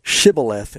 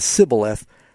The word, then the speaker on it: Shibboleth: You know, the Bible talks about a battle between Ephraim and the other tribes where if they couldn't say Shibboleth instead of Sibboleth, they were killed because they pronounced it.